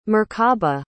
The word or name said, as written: merkaba